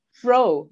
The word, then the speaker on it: Breault: Breault. – Okay, I would have pronounced it wrong. So even though it's spelled differently, you'll see in the show notes, it's Breault.